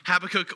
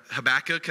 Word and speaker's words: Habakkuk: Most people actually, there's different ways to pronounce his name, Habakkuk, or I can't remember the other one. Habakkuk, I think is the other way people pronounce it, but it's a 3-chapter book.